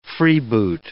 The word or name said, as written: freeboot